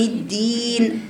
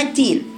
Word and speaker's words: الدين: يعني بتجيب نفس، يعني لما تيجي تقرأ مثلا مالك يوم مالك يوم الدين بتقرأها إزاي؟ قالك ينطقها كأنها التين، بيقرأها مالك يوم الدين لأن المخرج واحد، لكن هي جابت جرائن الهمس فيها بس أصبحت الدال إيه؟